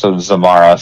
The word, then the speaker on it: Zamarah: I'm at Zamarah, so my name is spelled Zamarah and Dee. So Zamarah. So it's like Samara, but with a Z.